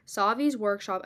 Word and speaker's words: Savi's Workshop: Is it Savi's Workshop? – Yeah. – Savi's Workshop, I guess it's where you build a lightsaber, or Ollivander's Wand Experience, where you can get a wand.